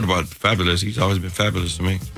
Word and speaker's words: Fabolous: She reunited with Fabulous. Is that the way I say that name? – You're saying it correctly. Look at you. Fabo. F-A-B-O-L-O-U-S.